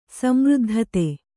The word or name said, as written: ಸಮೃದ್ಧತೆ